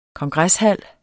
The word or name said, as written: kongreshal